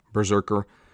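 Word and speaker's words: BRZRKR: pretty sure that's how you're gonna fucking pronounce it, it's B-R-Z-R-K-R, Berserker,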